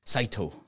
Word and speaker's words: Saito: Oh, Saito. – Saito. There you go. That's how that's more pronunciable.